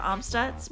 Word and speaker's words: Amstutz: And everyone can find all of my social media is at Sandra Amstutz. My last name is spelled A-M-S-T-U-T-Z.